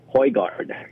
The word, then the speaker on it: Højgaard: Thank you for that story, because I don't think, and pronounce his name for me correctly. – Højgaard. – Højgaard.